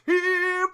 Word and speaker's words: gyro: Just going to get a gyro. Although it's technically pronounced hero. Although I still want to call it a gyro because it sounds cooler.